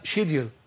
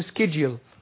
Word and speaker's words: schedule: الإنجليزي ينطقونها شيديل. – نعم. – شيديل. الإنجليزي يقولوا شيديل. والأمريكان يقول سكيديل.